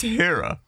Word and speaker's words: Hera: It's pronounced Hera. It's a Greek goddess.